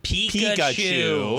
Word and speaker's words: Pikachu: So and even the Pokemon, the detective Pikachu.